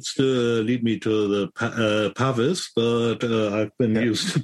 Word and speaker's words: Pavis: But so I would pronounce it Pavis. – Mm-hmm. – But I really like, I think it was maybe Chris Gidlow who said to pronounce it Pavi. And I like that. I like that because it's got a little bit of Frenchness in it.